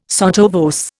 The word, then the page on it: sotto voce: Indian